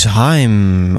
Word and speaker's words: Haim: Dit is Heem op ijs. op de Wikipedia. Ik doe het alsnog verkeerd. Het is Haim op uh, nummer 54 in mijn favoriete lijstje van 2020.